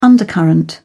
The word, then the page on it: undercurrent: British English